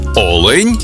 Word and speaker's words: олень: в однині, і в множині наголос падає на перший склад – олень та олені.